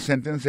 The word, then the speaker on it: sentence: Ça nous t'a dit dans sentence, c'est que accent tombe sous sen, sous premier syllabe là.